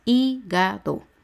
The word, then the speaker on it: hígado: Económico, hígado. Son tres sílabas, hígado. La antepenúltima es I, que lleva H al inicio.